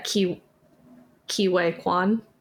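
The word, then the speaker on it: Ke Huy Quan: Anyway, I also want and think that Ki-Wei Kwan. Did I say that right? – Yeah.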